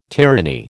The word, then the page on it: tyranny: American English